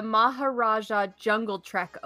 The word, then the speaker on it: Maharaja Jungle Trek: I hope I say this right. When did the Maharaja Jungle Trek open? Did I say that right? – Mm-hmm. – Yep.